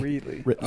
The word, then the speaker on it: Reedley: How do you say it? Say Reedley. – I usually say Ridley, but... – Yeah, some people Reedley. In Ridley say it like that.